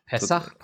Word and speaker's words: Pesach: So, wir sind zurück aus unserem kleinen Peschach und machen Pesach. jetzt Pessach, pardon, Pessach. sehr gut.